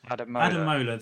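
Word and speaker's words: Ademola: Adamola, thank you very much.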